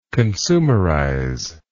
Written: consumerize

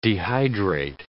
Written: dehydrate

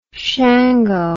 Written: shango

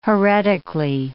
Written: heretically

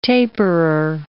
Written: taperer

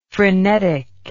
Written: phrenetic

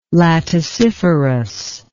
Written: laticiferous

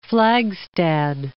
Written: Flagstad

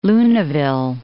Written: Luneville

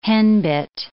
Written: henbit

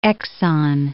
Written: exon

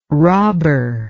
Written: robber

